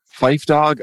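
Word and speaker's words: Phife Dawg: March 22nd, 2016, after years of health problems and a battle with diabetes, Fife Dog, did I pronounce that correctly? – Oh, yeah, you nailed it. – Fife Dog of A Tribe Called Quest dies at age 45.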